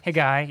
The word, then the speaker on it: Haggai: Haggai, how did you say it? – Haggai, yeah, that's how we're saying it.